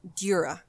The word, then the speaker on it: Djura: Is it Djura? – Djura.